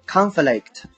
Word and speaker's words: conflict: OK，下一组单词 conflict，conflict 读作 conflict，重音在前面，它就是名词，斗争、冲突的含义。拼作 c o n f l i c t，这个单词呢，它的动词形式读作 conflict，conflict，它是动词，抵触或者是冲突的意思。接下来呢，我们来说一下名词 conflict 它的相关例句。